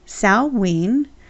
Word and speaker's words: Samhain: Sah-ween is that word that you see associated with Halloween. It looks like it says Samhain. It's S-A-M-H-A-I-N as Nancy. But it's pronounced Sah-ween because it's an old Gaelic word.